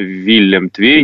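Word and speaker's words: Willem II: Сейчас это Фейенорд, ПСВ и Виллем Твей, который Виллем Твей не играл в Еврокубках с начала нулевых. Мне кажется, что я последний раз Виллем Твей, я почему-то вот запомнил, что он читается именно так, когда он со Спартаком играл в группе Лиги Чемпионов, вот как раз, наверное, это было там к нулевым ближе.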